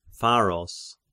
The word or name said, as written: Pharos